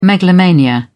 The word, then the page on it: megalomania: British English